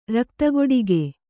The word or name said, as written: ರಕ್ತಕೊಡಗೆ